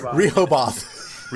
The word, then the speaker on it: Rehoboth: Rehoboth.